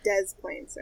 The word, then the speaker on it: Des Plaines: Um, figure it out for a place in a state where you don't pronounce the s. Yeah. Illinois, Des Plaines.